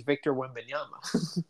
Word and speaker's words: Victor Wembanyama: He's Victor Wembanyama. – That's true. You nailed that pronunciation.